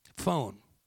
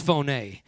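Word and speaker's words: phoné (φωνή): P-H-O-N-E. Phone. Right? We would pronounce it phone. In the Greek, it's phoné.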